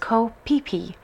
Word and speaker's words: Ko Phi Phi: Uh, Carolyn, you spent some time, you say, here on what I would say is the island of Ko Phi Phi, but you're just letting us know that it's pronounced differently in Thai. It's pronounced Ko Pipi.